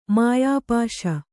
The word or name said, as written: ಮಾಯಾಪಾಶ